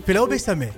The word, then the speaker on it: Filao Bessamé: Filao Bessamé. Je ne sais pas si je prononce donné... bien. Hein. – Ouais, c'est bien. Ouais, c'est bien dit. Filao Bessamé. – Ouais, c'est ça.